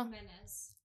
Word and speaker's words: Jimenez: Did I say that right? – Jimenez. – Say it again. – Jimenez. – Jimenez. – Jimenez. Jimenez. Yeah.